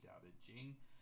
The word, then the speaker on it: Tao Te Ching: You'll see this spelled, I mean, currently everything's supposed to be spelled in pinyin, so it's spelled Daodejing, Tao Te Ching. But most people will see it as Taoteching. These are exact same words, exact same pronunciations.